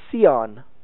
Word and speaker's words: scion: The grafting process, the term for it in English, it's spelled S-C-I-O-N, Sion. You can also render that Sion, Zion.